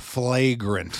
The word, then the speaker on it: Flagrant: Fragrant. How do you say it? – Flagrant. Flagrant. Like a flagrant foul.